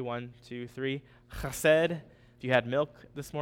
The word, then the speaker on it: chesed: And the Hebrew word is chesed.